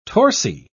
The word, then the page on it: torsi: British English